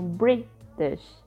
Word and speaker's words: British: Então, por exemplo, a palavra British, a, a sílaba mais forte é a primeira. Né? Então, British.